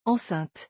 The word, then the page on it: enceinte: French